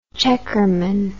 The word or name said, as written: checkerman